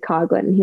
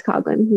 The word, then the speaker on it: Coughlin: So DJ from No Laying Up caddied for me at Pinehurst, and you know, on the first tee, they pronounce it correctly because I've told them. They said Coughlin. He's like, wait, your last name is pronounced that way? And I was like, yeah, it's Coughlin.